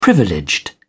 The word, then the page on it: privileged: English; British English